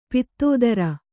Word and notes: ಪತ್ತೆದಾರ: Kannada

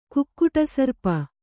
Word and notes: ಕುಕ್ಕುಟಸರ್ಪ: kukkuṭa sarpa

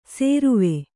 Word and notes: ಸೇರುವೆ: sēruve

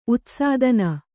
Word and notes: ಉತ್ಸಾದನ: kannada

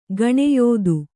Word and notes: ಗಣ್ಯತೆ: gaṇyate

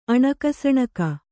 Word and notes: ಅಣಕಸಣಕ: Kannada

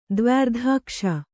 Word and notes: ದ್ವ್ಯರ್ಧಾಕ್ಷಿ: Kannada

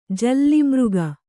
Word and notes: ಜಾಳಮಾರ್ಗ: Kannada

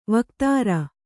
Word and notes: ವಕ್ತ್ರ: vaktra

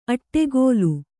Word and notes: ಅಟ್ಟೆಗೋಲು: aṭṭegōlu

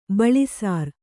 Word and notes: ಬಳಿಸಾರ್: baḷisār

